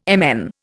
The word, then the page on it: MN: Indian